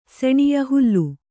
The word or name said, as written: ಸೆಣಿಯ ಹುಲ್ಲು